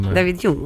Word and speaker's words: David Hume: David Hume, ok.